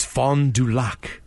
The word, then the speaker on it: Fond du Lac: Guys, yeah, I grew up in Wisconsin, and I just cringed as you butchered that name. – Oh, good. – It's Fond du Lac.